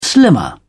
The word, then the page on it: slimmer: British English